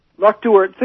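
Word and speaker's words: Loch Duart: Locke Duhart. Think Stewart with a D.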